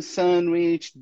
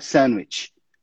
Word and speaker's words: sandwich: Essa aí, a gente não pronuncia aquele D. A gente simplesmente fala sandwich. Sandwich. A gente não pronuncia aquele D, tá? Sandwich.